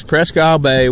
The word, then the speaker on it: Presque Isle Bay: It stretches from Cleveland all the way over to Buffalo, and we're south of there at Erie, Pennsylvania, in a place called Presque Isle Bay. Is that how you say it? – Yes, Presque Isle Bay, which is right off of Erie, PA, Erie, Pennsylvania.